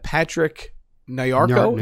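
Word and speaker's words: Patrick Nyarko: I have Patrick Nayarko, is that how you say his name? – Yes.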